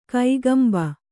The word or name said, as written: ಕಕ್ಕಂಬು